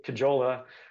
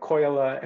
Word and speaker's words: Kajola: Also, when people heard my last name, so I say Kajola, which is a mispronounced version of the Finnish name, which I think is more like Koyala.